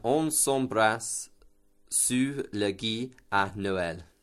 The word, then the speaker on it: gui: So the word for mistletoe is gui. – Gui. – Yeah, it's spelled G-U-I.